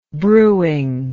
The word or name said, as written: brewing